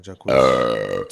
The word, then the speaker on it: Jacquees: Yo, song number three is I don't even know how to say his name, Jackie's. Featuring Dej Loaf. – Oh, okay. Jacquees.